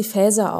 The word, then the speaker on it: Faeser: glaube, ehrlich gesagt, man spricht sie Phaser aus. Also sagen wir mal alle Phaser, aber es ist ja nicht Englisch.